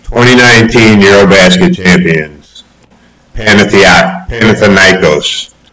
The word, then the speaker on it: Panathinaikos: Panathiakos. Do we have the pronunciation right? – Panathinaikos? – Panathinaikos.